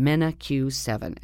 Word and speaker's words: MenaQ7: It's spelled Mena is M-E-N-A. And then the letter Q, and then the number 7, MenaQ7.